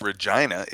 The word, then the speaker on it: Regina: It's pronounced Regina. – Is it Regina? – Yeah, it's a Latin word.